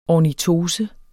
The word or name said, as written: ornitose